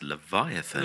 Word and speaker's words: Leviathan: So I can pronounce it on, like, Leviathan, is it? Vyant, so I'm just making sure. – That's or Levy. – That's Leviathan.